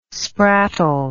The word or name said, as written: sprattle